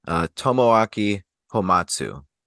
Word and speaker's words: Tomoaki Hamatsu: His real name, I looked up the pronunciation, Tomoaki Homatsu.